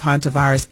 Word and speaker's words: Hantavirus: Hantavirus. – Hantavirus.